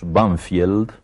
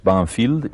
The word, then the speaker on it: Banfield: Sí, en uno de los suburbios de Buenos Aires que mm, nosotros pronunciamos Banfield, pero que tiene un nombre inglés, Banfield, y ese nombre inglés corresponde a uno de los eh, de los muchos ingenieros ingleses que construyeron el, sistema ferroviario argentino.